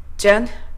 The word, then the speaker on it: general: ジェネラルであれば general general セブラルであれば several several ラテラルであれば lateral lateral と発音します。 R から L への音移動、ぜひマスターしてこの辺りの単語かっこよく発音できるようにしてみてくださいね。